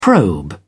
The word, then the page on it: probe: British English